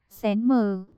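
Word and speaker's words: xén mờ (神马): Sao thần mã nhỏ hơn lớn hơn, từ này đọc là, xén mờ, đồng âm với từ nhỏ hơn lớn hơn, sờ hên mê sờ hên ma, nghĩa là, cái gì?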